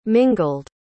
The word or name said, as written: mingled